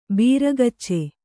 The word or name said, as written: ಭರಗಚ್ಚು